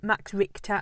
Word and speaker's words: Richter: But if you want to look him up, Max Richter, and it's spelled Max and then R-I-C-H-T-E-R.